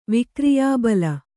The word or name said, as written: ವಿಕ್ರಿಯಾಬಲ